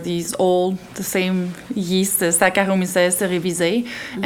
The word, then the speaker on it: Saccharomyces cerevisiae: So Saccharomyces cerevisiae.